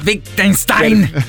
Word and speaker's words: Wittgenstein: Si pronuncia Wittgenstein. – Per chi conosce la filosofia, famoso, molto, molto famoso, molto famoso. – Ma, e... – ma non si pronuncia Wittgenstein? – Io sono pessimo.